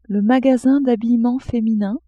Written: magasin d'habillement feminin

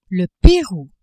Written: Perou

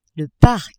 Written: parc

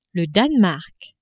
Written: Danemark

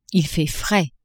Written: il fait frais

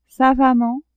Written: savamment

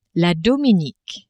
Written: Dominique

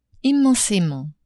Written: immensement

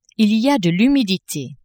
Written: il y a de l'humidite

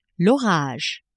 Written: orage

